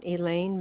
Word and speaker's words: Elaine: Elaine Melbardis.